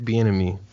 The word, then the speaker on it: Bieniemy: It's Eric Bieniemy.